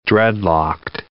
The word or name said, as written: dreadlocked